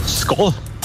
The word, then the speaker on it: Skål: Skål.